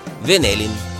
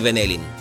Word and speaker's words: Венелин: Чували сте го Юрий Венелин? Айде де, голяма работа, че му се бърка ударението. Венелин е Венелин, Алабин Алабин, добре поне, че Пушкин не е Пушкин.